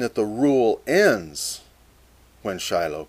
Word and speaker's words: Shiloh: As our translations reveal, one camp transliterates the Hebrew S-Y-L-H. Pronounced Shiloh. In the Hebrew, it's just the consonants, and you have to supply the vowels. And most commonly, Shiloh.